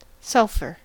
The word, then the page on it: sulfur: American English